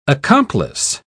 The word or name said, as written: accomplice